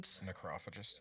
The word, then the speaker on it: Necrophagist: Necrophagist, and... – Necrophagist, yeah. – Oh, that's how you say it? – As far as I know, yeah.